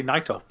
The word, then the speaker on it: Naito: Well, I say Naito because that's how the ring announcers call it. So I assume that's correct. – Yeah. – But a lot of people say Naito.